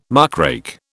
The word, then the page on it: muckrake: British English